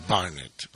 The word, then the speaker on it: Barnet: Posiblemente allí en Estados Unidos los gringos digan Barnett, Barnett. Pero a mí me suena mucho más musical Charlie Burnett. Bien, se escribe B-A-R-N-E-T. B-A-R-N-E-T.